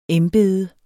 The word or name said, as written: embede